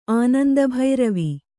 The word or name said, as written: ಆನಂದಭೈರವಿ